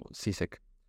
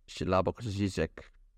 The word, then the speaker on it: Slavoj Žižek: Bueno, creo que se pronuncia así, se escribe Slavoj Žižek, españolizado podría ser así, pero por eso me encanta como Žižek. Žižek es como su pronunciación es adaptada a nuestro idioma, pero creo que su pronunciación correcta es algo así como Slavoj Žižek, o algo así.